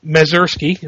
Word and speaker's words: Mazursky: Paul Mazursky, am I pronouncing that right? – Yeah.